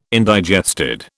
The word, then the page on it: indigested: British English